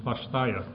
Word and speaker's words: Svasthya: He also co-founded the Svastha. You gotta tell me how to say it. Sorry, you'll tell us. Svasthya Project, a community health partnership in rural India designed to train women to be health providers and educators.